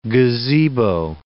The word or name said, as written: gazebo